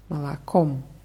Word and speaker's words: молоком: Die Betonung ist auf dem letzten O молоком.